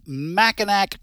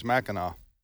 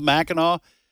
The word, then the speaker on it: Mackinac: I don't think that's the name of it, but go ahead. – Am I mispronouncing it? Yeah, you are. – Oh, what? – I think it's Mackinac. – Mackinac? – Yeah, I think. Okay. How's it spelled? – M-A-C-K-I-N-A-C. – I don't know.